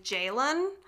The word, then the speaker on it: Ceylan: And as much as I want to pronounce her name right, it sounded like he was saying Jaylen. – Okay. – But it's spelled C-E-Y-L-A-N. – Okay. – So I'm going to go with Ceylan in my terrible American accent just because I don't want to be super wrong by trying to impersonate a British person saying this woman's name.